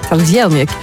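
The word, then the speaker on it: trdelník: Jens zegt al in de Nostalgie-app, je moet zeker eens de Tardelnik proeven. Eigenlijk moet je het zo uitspreken. – Tardelnik.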